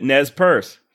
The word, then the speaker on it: Nez Perce: It's not exactly known how the breeding began, but the Nez Perce people did selectively breed horses. – All right, there you have it. That's three separate Nez Perce pronouncing it Nez Perce, just like I did.